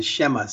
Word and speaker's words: Shema: That's known as the Shema, or the Shema, some people pronounce it.